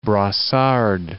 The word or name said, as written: Brossard